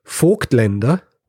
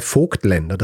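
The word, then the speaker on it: Voigtländer: Das rheinische Dehnungs-I, das wird einfach nicht ausgesprochen und wird bei ein paar Namen verwendet, unter anderem eben auch bei Vogtländer. Das heißt, jedes Mal, wenn ich Vogtländer gesagt habe,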